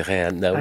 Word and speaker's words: Reinhardt: On se demande toujours comment on doit prononcer, c'est Reinhardt.